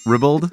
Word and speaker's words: ribald: I've never known how to say that. I know how to spell it. Yeah. R-I-B-A-L-D. Ribald? – Yeah. Ribald. It's like body.